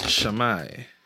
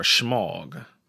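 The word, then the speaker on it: shemagh: It's pronounced shamai or shmog.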